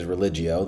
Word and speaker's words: religio: That is religio, the Latin word. And it's kind of like religion without the N on the end of it.